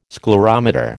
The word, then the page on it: sclerometer: American English